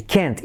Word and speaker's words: can't: I can't. Y la T no la pronunciamos. Simplemente acentuamos toda la palabra.